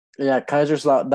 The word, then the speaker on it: Kaiserslautern: So our German team this year is FC Kaiserslautern. – That's the one. Yes. – Yeah. That's probably just completely butchered that, but I know it's a Schlotten. – Kaiserslautern.